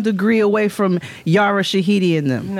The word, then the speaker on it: Yara Shahidi: How you say it? Yara Shahidi? Sharari? – Yara Shahidi. – Yara Shahidi.